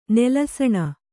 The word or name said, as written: ನೆಲಸಣ